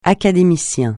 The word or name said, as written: académicien